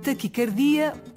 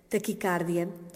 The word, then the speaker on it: taquicardia: Qual a pronúncia correta? Taquicardia ou taquicárdia? – Apesar de ser muito frequente a pronúncia com A aberto, taquicárdia, no domínio da medicina, segundo a etimologia da palavra, ou seja, segundo a origem da palavra, ela deve ser articulada com o A fechado, taquicardia. Porquê? Porque esta palavra provém do grego takis, T-A-K-H-Y-S, que em grego significa rápido, e o nome grego cardia, que significa em grego coração.